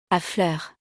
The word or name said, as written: à fleurs